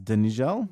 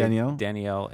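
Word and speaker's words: Danijel: It's Danigel? – No. The J's are usually pronounced as Y's. So I'd say Daniel and Zazelli.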